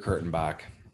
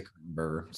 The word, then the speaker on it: Kurtenbach: Dieter Kurtenbach. I wanted to say Kurtenberger.